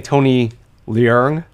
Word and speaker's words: Leung: So Job's saying Tony Leung because that's the Cantonese pronunciation, right? – That's correct. Yep.